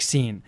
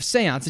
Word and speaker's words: séance: Because the seance is spelled like scene, but it's not pronounced like scene. So it doesn't make any sense that he's mishearing them as scene instead of seance.